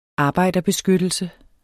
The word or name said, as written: arbejderbeskyttelse